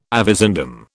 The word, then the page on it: avizandum: British English